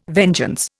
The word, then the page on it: vengeance: Indian